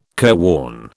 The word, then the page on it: careworn: British English